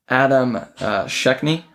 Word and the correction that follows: Schenk: How do you say that? Is that correct? Adam. Oh, Adam Schenk. Adam, Adam Schenk.